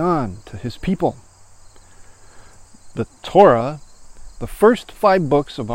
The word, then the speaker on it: Torah: it gives us the Hebrew word Torah, T O R A H, pronounced To and then Ra.